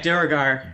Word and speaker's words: Duergar: Dragar? – Yeah, Duergar. – Duergar.